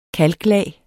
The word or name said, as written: kalklag